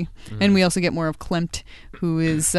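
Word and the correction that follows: Clent: Are you saying Klempt or Klent? – Klent. I said it wrong.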